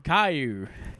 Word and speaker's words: Caillou: Like, I'll admit, I dig some of the cartoons today, except for, how do you say this? Kalayu? – Oh, Caillou? – Caillou. Except for Caillou.